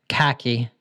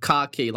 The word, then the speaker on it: khaki: I think this word is like khaki, like your car key.